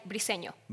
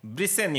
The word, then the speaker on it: Briceño: Or how do you pronounce it? – We say Briceño. – Briceño. – Because it's in Spanish. But Briceño. In English, they call me everything, so it's okay.